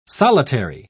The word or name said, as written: solitary